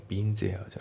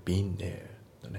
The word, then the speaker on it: been there: であと発音ですけど、その there なんですけど、それ there って発音されてないですよね。その been のね、あの前の n に引きずられて、その there のところが n で発音されてる感じですよね。 been there ね、ビンゼアじゃなくてビンネーだね。